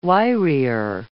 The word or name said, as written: wirier